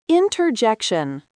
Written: interjection

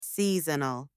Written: seasonal

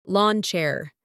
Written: lawn chair

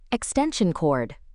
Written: extension cord

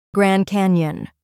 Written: Grand Canyon

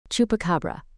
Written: chupacabra

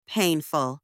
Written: painful

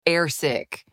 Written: airsick

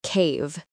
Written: cave

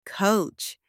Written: coach

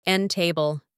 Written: end table

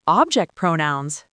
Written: object pronouns

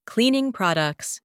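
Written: cleaning products

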